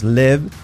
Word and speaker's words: live: Escrito live, live, cuidado la I, o sea que no sea live, que sea live con E. Y fijaos, no es live, live, es live. La, esa V que es una F sonora, live, live, live, very, river.